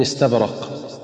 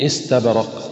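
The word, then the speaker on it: إستبرق: وكذلك من الأخطاء الشائعة في سورة الرحمن أيضا: متكئين على فرش بطائنها من استبرق، فالبعض ينطقها خطأ هكذا: من استبرق. والصواب أن ينطقها هكذا: من استبرق بسكون النون وكسرة الهمزة.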